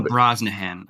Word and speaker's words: Brosnahan: Like, she can bring Rachel Brosnahan, I think is how you say it, by the way.